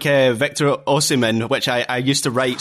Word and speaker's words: Osimhen: I have now gone with Osimhen. And I think that's what it is, Osimhen. I had it flipped the H before the M.